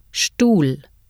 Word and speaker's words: Stuhl: The consonant combinations SP and ST are pronounced SP and SHT at the beginning of a word or word stem in German. Listen and repeat. – Sprache. Spielen. Spät. Sport. Stuhl.